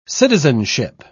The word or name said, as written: citizenship